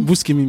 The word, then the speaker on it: Buscemi: Je, crois que Steve Buscemi Bouchemi. Bouchemi, merci.